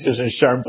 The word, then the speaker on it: SHRM: First of all, the pronunciation is SHRM, so thank you.